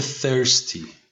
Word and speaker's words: thirsty: میشه thirsty. – Thirsty. – Thirsty. اینجا دوباره باید حواست به تلفظ TH باشه. TH تلفظش اینجا بین T و C هستش.